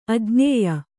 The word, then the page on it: ಅಜ್ಞೇಯ: ajñēya